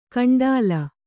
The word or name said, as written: ಕಂಡ್ಲಾ